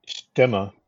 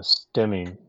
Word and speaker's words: Stemme: I don't speak any German, but I understand the proper pronunciation of STEMME in German is STEMA. But I think even the factory calls the airplane a STEMI nowadays.